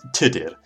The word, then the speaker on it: Tudur: Quick note here, the proper Welsh pronunciation of Tudor is Tudur, but for the sake of convenience, I will use the anglicised version the name eventually morphed into.